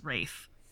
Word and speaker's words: Ralph: There's no way that that is like the appropriate spelling for a name pronounced Rafe.